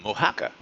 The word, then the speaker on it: Oaxaca: Oaxaca.